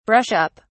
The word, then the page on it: brush up: British English